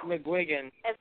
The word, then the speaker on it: McGuigan: Yeah, McGuigan.